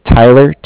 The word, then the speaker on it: Tyler: My name is Tyler, T-Y-L-E-R, and I'm with Bank of Cardiff. It's spelled C-A-R-D-I-F-F, as in Frank Brink.